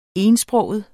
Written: etsproget

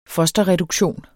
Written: fosterreduktion

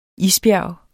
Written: isbjerg

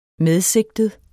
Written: medsigtet

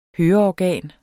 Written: høreorgan

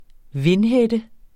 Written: vindhætte

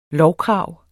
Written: lovkrav

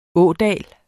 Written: ådal

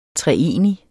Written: treenig

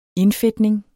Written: indfedtning

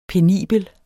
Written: penibel

